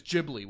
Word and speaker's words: Ghibli: You don't need to fucking live there to see that way they pronounce it is Ghibli with a soft G.